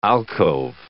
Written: alcove

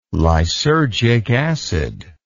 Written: lysergic acid

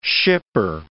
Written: shipper

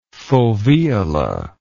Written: foveolar

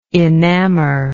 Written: enamoredness

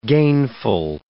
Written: gainful

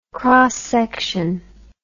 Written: x-section

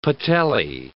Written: patellae

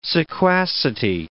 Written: sequacity

